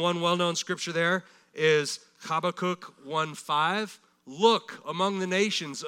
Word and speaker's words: Habakkuk: You can just read that word and know you're never going to get that one right, pronouncing it right. It's actually Habakkuk. Habakkuk.